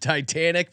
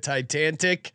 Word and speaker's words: Titanic: Catching my mispronunciation of Titanic by calling it Titantic.